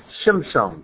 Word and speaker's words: Shimshon: She called his name Shimshon. That's how it's pronounced in Hebrew.